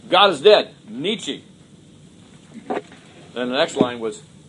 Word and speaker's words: Nietzsche: And there was a guy named Friedrich Nietzsche, kind of a funny, you know, kind of rhymes with peachy.